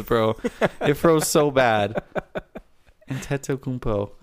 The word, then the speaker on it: Antetokounmpo: Antetokounmpo. That's what his pronunciation says on Basketball Reference.